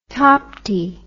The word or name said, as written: Tapti